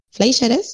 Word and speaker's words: Fleischer: No, lo que quería decir es que esto que mencionaste hoy de volviendo a los hermanos Fleischer, Fleischer sí, se pronuncia así.